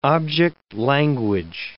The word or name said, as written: object language